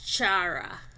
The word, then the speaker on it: chara: The sound is chi. An example is chara.